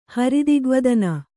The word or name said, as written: ಹರಿದಿಗ್ವದನ